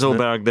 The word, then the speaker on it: Åberg: It is Åberg. They're giving it the hard G.